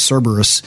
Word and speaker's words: Cerberus: named Kerberos, or I think that's the Greek pronunciation. Latin is Cerberus.